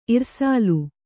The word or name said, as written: ಇರ್ಸಾಲ್